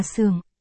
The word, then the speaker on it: Sường: Không đâu đừng nha, Gen, từ này cha Hán Việt thì có hai âm đọc là Sướng và Sường. Dùng cho tên hoặc họ thì là Sường.